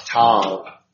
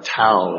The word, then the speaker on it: Tav: This is the, depending on how you learned how to pronounce one letter, in the New American Standard it has Tav. Okay? And and you notice in your notes, I have Tau. That's the way I learned how to pronounce this letter.